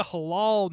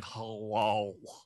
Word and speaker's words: halal: Oh yeah, it, it'll be a halal Manhattan tour. I just hate saying halal. Halal. Halal. Is that how you pronounce it? – Yes, I believe so.